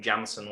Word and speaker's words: Jansen: I'm calling him Jansen, by the way, and I'm doing the same for Danny Jansen. That J should not be pronounced, and I don't even care if he denies that himself.